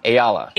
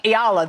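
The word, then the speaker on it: Eala: Resale prices for tonight's session featuring Alexandra, how do I say this, Dom? – Ayala. – Ayala, thank you, have doubled in just the past few days.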